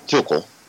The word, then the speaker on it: Tuchel: Well, the first problem, Critty, is they should have never fired Thomas Tuchel. Am I pronouncing the U right? – You are, yes, yeah, and I agree with that.